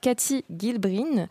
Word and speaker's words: Cathy Gilbrin: La photographe Cathy Gilbrin euh, oui c'est comme ça que ça se prononce,